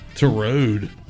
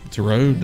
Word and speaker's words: Tureaud: Turode. – T-U-R-E-A-U-D. That sounds French. How would you say that? Turode? – Yeah. – Turode.